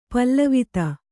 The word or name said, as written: ಪೇಲವತೆ